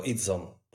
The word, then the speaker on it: Idzon: Raczej krótko, Idzon. Po prostu, bez E na końcu, okay.